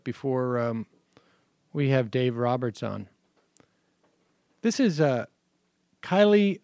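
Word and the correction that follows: Kayleigh: McEnany. Kaylee McEnany. I'm sorry.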